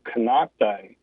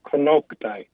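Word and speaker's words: Konocti: It's pronounced Konoktai. And so the word Kono means mountain, and Ktai is basically plural for two older women.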